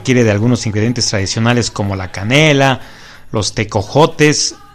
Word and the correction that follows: tejocotes: si lo, lo dije bien, te, eh, tejo, teco, tejocotes, ¿verdad? Tejocotes. Está perfecto esto, ¿verdad?